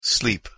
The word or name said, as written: sleep